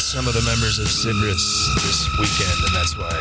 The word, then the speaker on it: Sybris: That's spelled S-Y-B-R-I-S. I believe it's pronounced Sybris.